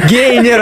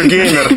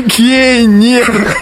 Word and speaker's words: гейнер: Гейнер от слова гейн. Приобретать, то есть наращивать. – Я же говорю геймер. – Гейнер.